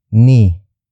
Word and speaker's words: ni: Word for word, it's napakaganda ni Pia.